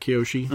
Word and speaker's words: Kyoshi: Kyoshi Battle Fan. Am I saying that right, Kyoshi? – Uh-huh.